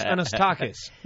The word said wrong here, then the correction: Anastasakis: No, Anastasakis. Anastasakis. – Anastasakis.